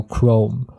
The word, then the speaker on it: Chrome: 所以它是发作叫 chrome。